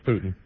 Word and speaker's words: Putin: But I wanted to comment on McCain's remarks about how do you pronounce his name, Putin or Putin or whatever it is. – Putin.